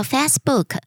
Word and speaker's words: Facebook: Nina，故事很好听，但是想要提醒，因为你常常会说到 Facebook， Fast 的发音应该是 Face 而不是蝴蝶音，谢谢你。